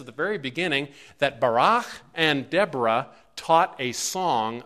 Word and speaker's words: Barak: There's Deborah and Barak, not Barack Obama, okay? His name is Barak, like Ehud Barak.